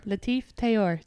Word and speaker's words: Latif Tayour: Latif Tayour.